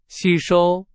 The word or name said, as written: 吸收